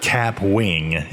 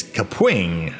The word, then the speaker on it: Kapwing: I was calling it Kapwing. It's not Kapwing. It's Kapwing, apparently. So I was pronouncing it completely wrong.